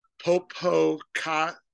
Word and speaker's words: Popocatépetl: And so this is called El Popo, but the actual way to pronounce it is Popocatépetl volcano.